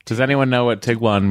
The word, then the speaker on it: Tiguan: like Tiguan. – Yeah. – Tiguan. But it's Tiguan.